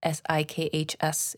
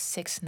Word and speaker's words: Sikhs: And yes, by the way, Sikhs, S I K H S, is pronounced Sikhs, not Sikhs.